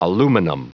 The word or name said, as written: aluminum